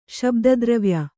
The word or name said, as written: ಶಬ್ದದ್ರವ್ಯ